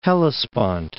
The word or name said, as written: Hellespont